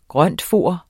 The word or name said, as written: grøntfoder